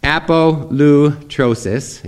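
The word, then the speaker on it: apolutrosis (ἀπολύτρωσις): The New Testament concept, the Greek word is apolutrosis, if you want to pronounce it correctly.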